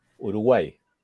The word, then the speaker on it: Uruguay: How do you say it? – Uruguay. – Uruguay.